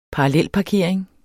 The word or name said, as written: parallelparkering